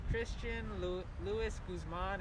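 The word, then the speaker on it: Christian Louis Guzman: My name is Christian Luis Guzman. It's C-H-R-I-S-T-I-A-N-L-O-U-I-S-G-U-Z-M-A-N.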